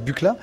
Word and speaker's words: Buchla: c'est Donald Buchla, ou Buchla, enfin ça se prononce Buchla,